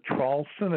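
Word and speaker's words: Traulsen: My name is Hugh, H-U-G-H. The last name is Trawlson. It's spelled T like Tom, R-A-U-L-S-E-N like Nancy.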